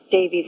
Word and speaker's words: Davies: People tend to leave off the E and think my last name is Davis. But in Wales, Davies is one of the most common surnames,